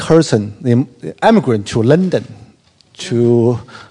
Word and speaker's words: Herzen: Herzen. Herzen. – Alexander Herzen.